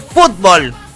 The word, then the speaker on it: fútbol: Pero el fútbol le pone el acento en la O. Es muy yankee eso. El fútbol. Es fútbol con acento en la U.